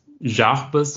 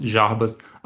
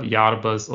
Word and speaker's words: Jarbas: Also mein Name ist, du hast auch richtig gesagt, ist Jarbas. So, also ich komme aus Brasilien, wir sagen dort Jarbas, aber Jarbas oder Jarbas ist alles ist gut.